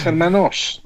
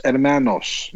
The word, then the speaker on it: Hermanos: Well, it's spelt with an O. It's Hermanos. – Well, say it again for us. – Los Pollos Hermanos.